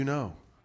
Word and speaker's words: know: Once they have the reveal, now you starts with K, but you don't pronounce it. No. Now you know.